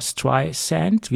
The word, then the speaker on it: Streisand: weil Siri hat es bis vor ein paar Monaten mit Z ausgesprochen und nicht mit S wie Sand. Das heißt ja Barbara Streisand, wie der Sand am Strand.